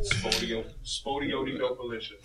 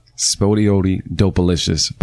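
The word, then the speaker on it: Spottieottiedopaliscious: How do you say it? What is it? – Spodioli Dopalicious. – There you go. Spodioli Dopalicious by Outkast.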